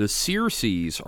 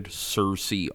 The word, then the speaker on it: Searcy: relatives of the folks that founded Searcy, Arkansas. – Hmm. – So, just a little aside there, as we're going forward, it's actually pronounced Searcy, like search.